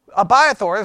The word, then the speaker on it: Abiathar: Or Abiathar, or Abiathar, however the way you want to pronounce it, because I've heard it so many different ways this week, okay? But Abiathar is the way we're going to go with it.